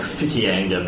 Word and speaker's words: Whitianga: Was it Coromandel or was it Whitianga? – It was Whitianga? – Except for, I think that's supposed to be like Fikianga because WH is Phil.